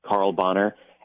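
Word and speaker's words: Carl Bahner: It's just at Carl Bonner.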